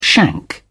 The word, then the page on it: shank: British English